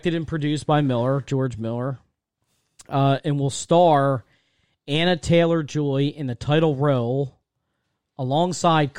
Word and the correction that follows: Anya Taylor-Joy: Apparently, I think it's Anya Taylor-Joy, I think that's how you're supposed to pronounce it, is going to be playing Charlize Theron's character.